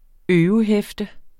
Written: øve

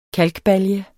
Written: KALKBALJE

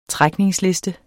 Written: trækningsliste

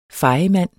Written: fejemand

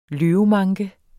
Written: løvemanke

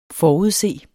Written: forudse

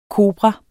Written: kobra

